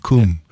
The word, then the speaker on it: come: The people who are from the Midlands and farther north in England, they don't say come with that O sound. It's like a U sound, cum.